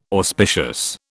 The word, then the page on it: auspicious: British English